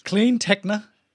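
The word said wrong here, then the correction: CleanTechnica: How do I can never pronounce it. – Clean Technica. – Thank you.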